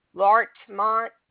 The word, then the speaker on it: Larchmont: Larchmont, New York? – Yes.